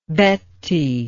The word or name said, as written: Betti